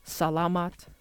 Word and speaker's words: salamat: The first one is the word salamat, and it's spelled S-A-L-A-M-A-T, and it means thank you. And from what I can tell, the emphasis is on the first A in that word, so salamat, salamat, thank you. Next is the word wala, spelled W-A-L-A, and it means nothing.